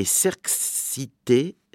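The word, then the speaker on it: Šerkšnytė: et Serxite c'est le nom. Alors ça s'écrit S avec un petit V comme en tchèque, E-R-K-S, à nouveau un petit V, Y-T-E, et il y a un point sur le E.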